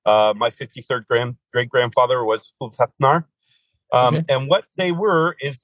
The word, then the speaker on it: Ulfhethnar: Ulfidnar? What does that mean? – Close, close. You got Ulv, OOLV, Ulv. Heth is HETH, and then NAR. And Ulfhethnar, those were warriors, according to Norse legend.